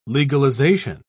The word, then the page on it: legalisation: British English